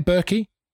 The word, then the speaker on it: Berkey: Oh, yeah, the Berkey, big, is that how you say it, Berkey? – Yeah.